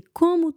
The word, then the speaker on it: com: That M at the end of com is just nasal. Think of C-O-N-G, Kong, but don't pronounce the G.